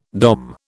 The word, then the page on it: Dom: British English